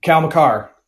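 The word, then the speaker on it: Makar: Was it Cale Maker that Duchene? Was it Cale Maker that they drafted for the Duchene pick? – Cal McCarr. Yeah, you're right. That's him. – Sorry, McCarr. I say it wrong.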